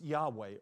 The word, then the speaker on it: Yahweh: And today, most Hebrew scholars believe that God's name should be pronounced Yahweh or Yahweh.